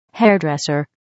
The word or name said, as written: hairdresser